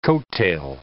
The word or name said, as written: coattail